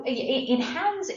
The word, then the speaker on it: Enhanze: So Enhanze is an enzyme.